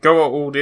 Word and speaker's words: Goa'uld: Goa'uld is the way you're supposed to pronounce it.